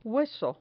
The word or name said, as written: hueso